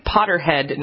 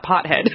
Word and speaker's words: Potterhead: Yes, we should say Potterhead, not Pothead.